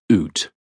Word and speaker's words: Uht: Ut, UHT, pronounced Oot,